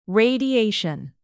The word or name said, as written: radiation